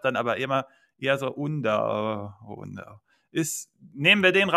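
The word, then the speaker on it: Undav: Ähm, äh, wie, mir wurde gesagt, ich darf niemals Undaf sagen, das ist auf jeden Fall falsch.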